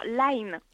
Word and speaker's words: Line: Qu'est-ce que c'est l'application Lean bah, Souvent vous parlez qu'il faut, pour contacter les gens, il faut être sur Lean. Alors je le prononce peut-être mal. Hein. – Ah, alors Line.